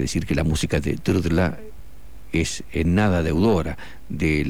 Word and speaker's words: Drdla: El compositor checo František Drdla. Es un apellido bastante difícil de pronunciar. Se deletrea D-R-D-L-A y se pronuncia así, como está escrito.